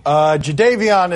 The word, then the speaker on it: Jadeveon: Jadeveon is how we pronounce that.